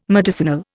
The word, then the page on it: medicinal: Indian